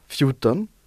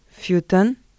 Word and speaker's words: fjorton: 14, Fjorton. – Fjorton. – Qui s'écrit F-J-O-R-T-O-N.